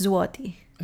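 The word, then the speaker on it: złoty: Also, it's zloty. – Zloty, that'd be right, yeah. – So the L with a dash on it, which is super Polish, is pronounced like a W. So their currency is zloty.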